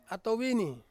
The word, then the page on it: ato vini: Ansus